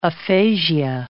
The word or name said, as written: aphasia